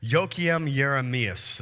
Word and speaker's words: Joachim Jeremias: Ran across the commentary of Joachim Jeremias.